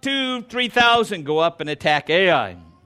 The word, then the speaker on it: Ai: Joshua sent men from Jericho to Ai, or Ai, depends on how you pronounced it growing up, which is near Bethaven, east of Bethel, and said to them, "Go up and spy out the land."